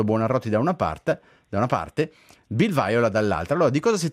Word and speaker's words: Viola: In Inghilterra dicono Viola o Bill Viola? – Viola. – Ok, manteniamo allora la dizione corretta.